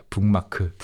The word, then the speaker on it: 북마크: So the first syllable becomes 붕. – Yeah, 붕. – Because of the 미음 that comes after. 기어. Yeah, 붕마크.